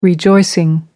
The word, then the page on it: rejoicing: American English